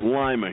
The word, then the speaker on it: Lima: They pronounce it Lima here. It's Lima, Peru, but it's Lima, Ohio.